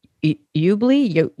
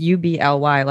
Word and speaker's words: Ubly: I feel like anybody not from there tries to pronounce it, they're like, Ubley, it's Ubly, like ugly with a B.